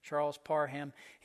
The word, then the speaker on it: Charles Parham: It's just spelled like it sounds, Charles Parham.